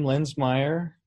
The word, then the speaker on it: Linsmeyer: My last name, Linsmeyer, spelled like the town of Linz, but with an S instead of a Z.